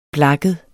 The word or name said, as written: blakket